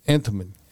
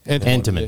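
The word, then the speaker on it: anemone: Antimony? Antimone? A-N-T-E-M-O-N-E? – Antimony. – Antimony.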